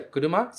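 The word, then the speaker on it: kuruma (車): E esse kanji, se pronuncia kuruma, significa carro. Então, no final da frase, a gente tem kuruma desu, kuruma desu.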